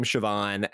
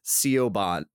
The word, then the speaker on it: Siobhan: And if I had never seen the name Siobhan and been told how to pronounce it, Siobhan. – Yeah, Siobhan. And it's like they love to put an H right after a consonant.